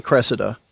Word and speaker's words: Cressida: I say Cressida. It's spelled C-R-E-S-S-I-D-A.